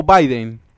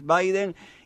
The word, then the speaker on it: Biden: Claro, Biden es la producción en inglés, pero para la gente que no sabe o lo lee en los medios de comunicación Biden y vuelve a pronunciar, entonces, bueno, se escribe Joe Biden, pero es Biden.